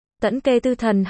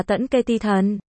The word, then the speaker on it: Tẫn Kê Tư Thần: Tẫn Kê Tư Thần hay còn đọc là Tẫn Kê Ti Thần, nghĩa đen là gà mái gáy vào sáng sớm giống như gà trống.